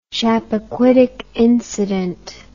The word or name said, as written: Chappaquiddick incident